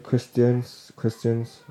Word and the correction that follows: Christensen: or whatever his name is. How do you say his name? – Sorry, I'm Christensen.